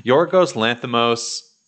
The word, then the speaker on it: Yorgos Lanthimos: Yorgos Lanthimos.